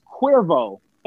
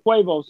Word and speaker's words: Cuervo: Like, he might drink that shit and not be aware that it's Cuervo and not Quavo. He's like, yeah, I drink Quavo, so I'm gonna call myself Quavo.